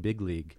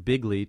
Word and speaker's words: big league: like we're going to win big league. But because it's the way he said the word, very often that g sound at the end was not fully released, it sounded like bigly to many people, perhaps most people, when they heard it, for instance, in the debates against Hillary Clinton.